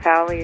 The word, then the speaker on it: Talley: And Christine is spelled C-H-R-I-S-T-I-N-E. And Talley is M as in Mary, and Talley is T-A-L-L-E-Y.